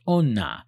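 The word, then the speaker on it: onna (女): The written Japanese kanji symbol for woman is pronounced onna and consists of three strokes, its ultimate appearance looking a bit like a stick figure.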